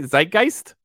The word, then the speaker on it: zeitgeist: Zeitgeist? – Yeah, that's the word.